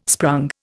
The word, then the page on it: sprung: Indian